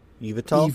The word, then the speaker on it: Yveltal: Yveltal.